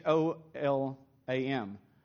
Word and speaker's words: Gholam: In fact, his actual name is, um, is Golom, okay? Or Olam, but it's, it's spelled like Golem, okay? It's spelled G H O L A M. It's Olam.